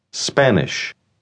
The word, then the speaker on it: Spanish: Spanish. Spanish. Ponga atención al principio de la palabra. Note que usted debe decir sp y no ESP.